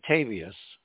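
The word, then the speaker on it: Petavius: I think it's pronounced Patavius.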